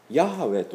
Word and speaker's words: ヤハウェ: で、今日私たちはこの聖書で、旧約聖書で主と訳されている言葉がありますが、これはヤハウェというふうに発音するんですね。